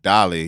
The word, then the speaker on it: Dolly: Is it Dolly or Dally? – Dolly. Yeah, Dolly. Like Ali, okay. But with a D.